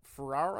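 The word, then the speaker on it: Ferrer: Ferrar, I believe is how you say his name.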